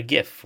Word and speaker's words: GIF: He called it a GIF with a soft G.